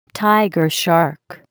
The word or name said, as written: tiger shark